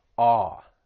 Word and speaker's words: awe: When pronouncing this word, it's just that vowel sound, awe, like you would have in the word saw.